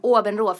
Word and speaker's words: Åbenrå: Åbenrå, för det ligger i Danmark, så då uttalas det så.